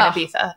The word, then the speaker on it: Ibiza: We hope you're like dancing on a table somewhere in Ibiza.